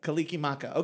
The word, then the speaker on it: Kalikimaka: How do you say that? – Mele. – Kalikimaka.